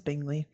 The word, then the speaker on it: Bingley: And then second note, his name is Bingley, which is how I pronounce it. Is that how you pronounce it as well? – Yep. Bingley.